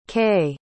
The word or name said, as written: kei